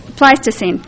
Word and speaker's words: Pleistocene: Phil replied that it was one of the best illustrations of the Pleistocene period of the Ice Age. Am I saying that right? I'm not a geologist. Pleistocene, right. Left the S out.